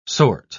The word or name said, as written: sort